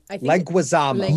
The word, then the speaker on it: Leguizamo: Leguizamo? – Leguizamo.